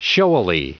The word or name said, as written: showily